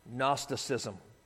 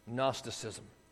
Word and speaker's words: Gnosticism: Gnosticism. You can write that down. It has a silent G up front. Gnosticism.